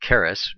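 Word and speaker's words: charis (χάρις): Did I say charis? I meant charis. It's the CH is pronounced like a K in English.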